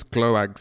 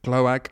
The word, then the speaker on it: Gloag: Thomas Gloag, sixth Gloag. I get the pronunciation yips every time I have to pronounce his name. G-L-O-A-G, and he's English.